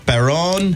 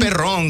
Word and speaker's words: Perdón: Perdón. – Perdón. – No perrón, because then you'd be calling him a dog. – Perdón. – Perdón.